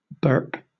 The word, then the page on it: burp: British English